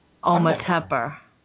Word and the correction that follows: Ometepec: Yeah, Ometepec.